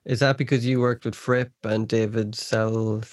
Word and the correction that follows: Sylvian: Sylvian. – Yeah. Damn, I always say his name wrong.